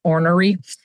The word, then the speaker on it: ornery: I would call them ornery. I think that word is supposed to be pronounced ornery.